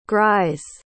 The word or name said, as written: grise